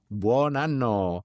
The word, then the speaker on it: Buon anno: Buon anno.